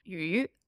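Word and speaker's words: yu (鱼): So the word for fish, yu, sounds like the word for surplus.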